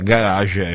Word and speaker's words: garage: Cioè garage è francese e lo pronunciamo come lo pronunciano i francesi, però ci sono altre...